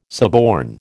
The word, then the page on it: suborn: American English